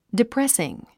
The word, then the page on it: depressing: diprésiŋ; ディプレスィン